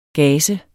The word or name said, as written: gazer